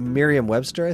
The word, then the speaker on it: Merriam-Webster: Is it Merriam Webster, I think? Merriam Webster, I think? – Oh, hell, have I been saying it wrong all these years? – M-E-R. – Let me look on my app because I have it. Oh, you're totally right. It is Merriam-Webster. You know, you wanted to start the episode over again.